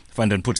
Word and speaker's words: Vandenput: So my surname is pronounced Vanderput. – Vanderput.